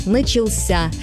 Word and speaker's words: начался: Посмотрите, в неопределенной форме мы говорим «начаться». Ударение падает на второй слог. А вот в форме прошедшего времени мужского рода ударение уходит в конец – «начался».